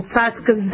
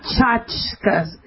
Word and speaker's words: tzatzka: it's tzatzka, not tchotchka. You guys mispronounce that. You call like little toys or gadgets tchotch. I don't understand that. It's tzatzkas. They're called tzatzkas, like a TS, like with a tzadi.